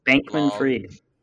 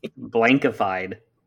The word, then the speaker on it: Bankman-Fried: Bankman-Fried. – What did I say? – Blankified.